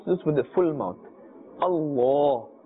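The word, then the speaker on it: Allah (الله): As you can see, there is a vertical fatha, and therefore we must pull according to the duration of one alif, and we must pronounce this with a full mouth, not with a flat sound. We should not say Allah.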